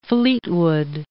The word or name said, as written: Fleetwood